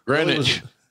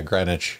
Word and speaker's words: Greenwich: Greenwich. – He put Greenwich, but yeah, Greenwich. There you go. – Yeah, well, it's spelled Greenwich. It is. It's Greenwich.